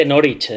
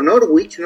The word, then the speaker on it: Norwich: No, él, no decía exactamente Norwich.